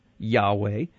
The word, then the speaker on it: Yahweh: usually pronounced Yahweh,